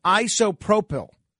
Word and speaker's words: isopropyl: I want to thank Bernard Goetz, a friend of mine and a regular listener to this show, who sent me the proper phonetic pronunciation of the type of alcohol that I was looking for. It is isopropyl.